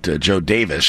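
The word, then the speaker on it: Jo Daviess: Well, apparently one of the founders of communities back there pronounced it Joe Davis.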